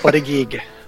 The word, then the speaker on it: Buttigieg: Pete Buttigieg? – Buttigieg.